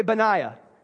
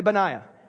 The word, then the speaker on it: Benaiah: Everybody say Benaiah. Come on, say it like you just had a Red Bull. Everybody say Benaiah.